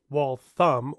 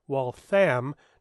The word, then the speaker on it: Waltham: According to parents at one high school in Waltham, Massachusetts, well, pronounced Waltham or Waltham, depending on whether you're a Southie or a Flatlander, the book their school assigned for the summer was inappropriate due to violent themes.